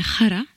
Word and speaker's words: Khara: On va écouter votre deuxième titre, Xapa. Ça se prononce comme ça? – Eh bien, en fait, ça s'appelle Khara, ça se dit Khara.